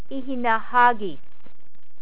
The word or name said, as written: ihinahagis